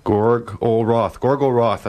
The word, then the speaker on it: Gorgoroth: Gorgoroth. That's how you say it.